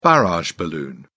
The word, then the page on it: barrage balloon: British English